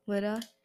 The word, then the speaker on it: Lydda: Lydda.